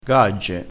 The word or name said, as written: gagge